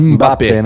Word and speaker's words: Mbappé: A gente fala Mbappé e não é Mbappé. – É Mbappé, né? Mbappé. – É o M e o B é um som só.